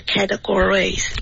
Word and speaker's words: categories: I've broken them down into categories. – OK, 这个 down 的发音非常好。然后 categories 的重音呢是 ka 而不是 gore, OK, ka. – 嗯 ,H-Z-H.